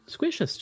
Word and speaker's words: squishous: Yeah, squishous. Just... – Oh, I thought it was, like, rhyming with vicious. – No, squishous.